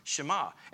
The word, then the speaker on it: Shema: That verse is called the Shema, because the first word in Hebrew is pronounced Shema, and it literally means hear or listen and obey.